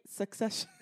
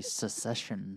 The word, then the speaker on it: Succession: And am I saying that right? Succession? – Yes. For some reason, you say secession sometimes.